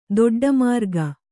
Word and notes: ದೊಡ್ಡಮಾರ್ಗ: Kannada

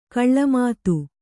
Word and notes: ಗಿಳಿಮಾತು: kannada

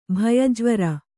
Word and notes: ಭಯಜ್ವರ: Kannada

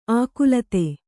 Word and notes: ಆಕುಲತ್ತೆ: ākulatte; kannada

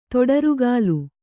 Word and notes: ತೊಡರುಗಾಲು: Kannada

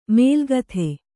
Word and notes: ಮೇಲ್ಗಥೆ: mēlgathe

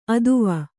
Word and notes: ಅತ್ವ: atva

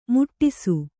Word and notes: ಮುಟ್ಟಿಸು: muṭṭisu

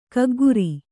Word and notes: ಕಕ್ಕುಗುರು: kakkuguru

